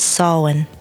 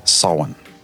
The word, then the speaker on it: Samhain: No, the proper way to say it is Samhain. – Samhain.